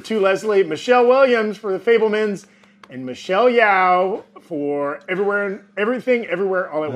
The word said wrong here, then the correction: Michelle Yeoh: Michelle Yeoh is how her name is pronounced. Apologies, I mispronounced her.